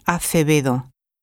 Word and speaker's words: Acevedo: T-I-M-A-N-F-A-Y-A. – ¿Y cómo era su nombre? – Isabel Acevedo. A-C-E-V-E-D-O.